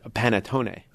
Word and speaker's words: panettone: I looked up the correct pronunciation for the word panettone. Panettone, yeah. – yeah did I say it right? Okay. Well, almost. Mm. Panettone. Panettone. Not pan, not pan.